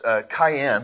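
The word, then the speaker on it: cayenne (קין): And it's actually pronounced cayenne, kind of like cayenne pepper. So cayenne, we can call him cayenne, or you can call him Cain.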